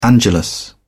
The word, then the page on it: angelus: British English